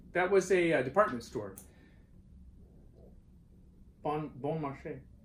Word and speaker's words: Beaumarchais: Because you're actually saying it correctly, and it's not difficult. Beaumarchais.